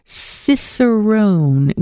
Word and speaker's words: Cicerone: Michelle Tham is with me live, who's head of beer education at Labatt and is a certified Cicerone, which is a wine sommelier, but for beer. How did I do in that pronunciation? – Perfect.